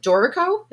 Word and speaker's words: Dorico: I saw that you have a relationship with Dorico.